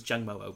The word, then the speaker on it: Jangmo-o: The evolution of Jangma-O, as you've been pronouncing. – Jangma-O. – Jangma-O. It was Jangmo-O, but whatever.